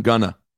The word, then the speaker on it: Gunna: Gunner? – Young Thug and Gunna. – Gunna, sorry, Gunna. – You have Gunna. To say his name right. It's Gunna. Gunna.